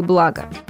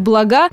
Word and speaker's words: блага: Но мы уже скорее скажем, что нас окружают материальные блага. Причем хорошо, если именно поставим ударение на первый слог и никак не на второй, потому что вариант «блага» – грубейшая ошибка.